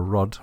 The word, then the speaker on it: Rudd: In 2016, Amber Rudd, rhymes with dud, during her tenure as Home Secretary, announced her own crackdown.